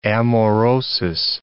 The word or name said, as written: amaurosis